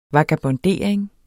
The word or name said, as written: vagabondering